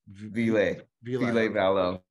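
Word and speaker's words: Ville Valo: Vile Valo.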